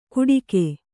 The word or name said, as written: ಗುಟಿಕೆ